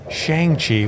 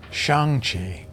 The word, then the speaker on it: Shang-Chi: And despite pronouncing it Shang-Chi when the movie is saying it's Shang-Chi, yes. – I'm still going. – We've been saying it wrong, apparently, due to Steve Englehart's error.